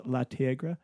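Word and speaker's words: Le Tigre: which made me think of Kathleen Hanna's La Tigre.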